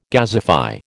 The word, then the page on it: gasify: American English